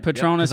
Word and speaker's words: Patronus: where, for example, Harry gets saved by a Patronus. Did I pronounce that right? – Patronus.